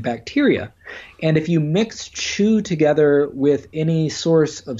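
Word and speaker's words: Qu: And the most important thing to happen during these ancient dynasties was the invention of something called Chu, which is spelled Q-U.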